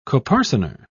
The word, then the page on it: coparcener: British English